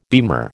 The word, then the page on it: beamer: American English